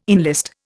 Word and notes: enlist: Indian

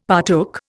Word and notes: partook: Indian

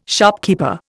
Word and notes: shopkeeper: Indian

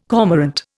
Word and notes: cormorant: Indian

